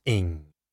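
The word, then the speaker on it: Ng: Cheetah, pronounced Cheetah, and N-G, pronounced Ing.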